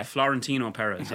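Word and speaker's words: Florentino Pérez: Florentino Perez. – Yeah. That was very yeah well pronounced.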